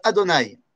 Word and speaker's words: Adonai: Vous savez, dans ce monde, Akadosh Baruch Hu, Yudke Vavke, se prononce Adonai.